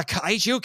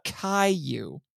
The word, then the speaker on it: kaiju: Oh, and we have an old scientist who says the kaiju can't be stopped. Oh, wait, no, I'm sorry. The Caillou. – Yeah, she had a pronunciation problem.